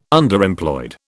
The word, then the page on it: Underemployed: British English